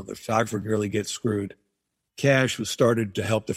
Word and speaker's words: Cache: I'm with the Cash Agency, which is it's spelled C-A-C-H-E. So it looks like cachet, but it's pronounced Cash Agency.